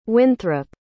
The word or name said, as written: winthrop